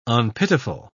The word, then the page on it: unpitiful: British English